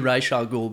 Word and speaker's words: Ra's al Ghul: His pronunciation should be Ra's al Ghul, because that's how it was in the animated series.